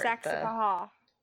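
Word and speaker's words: Saxapahaw: dang it, Saxapaw? Did I leave out a syllable? – That's, you kind of, You kind of didn't give the ha part. – Saxapaha.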